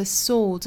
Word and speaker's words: sword: I know that the spades are the swords of a soldier. 第二个需要注意的词就是剑，这个词就是 sword. 注意这个词它字母 w 是不发音的，这个单词呢只包含一个元音，就是 o，所以呢再加前面的这个辅音就是 soul，那再加后面的 the s 读 z， swords， swords。